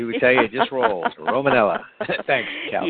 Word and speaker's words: Romanella: Yeah, and it's Romanella. – Did I say that wrong? Romanella.